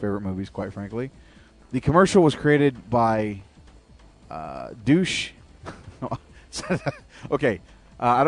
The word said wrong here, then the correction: Deutsch: It's Deutsch. There we go, Deutsch. It's not Douche.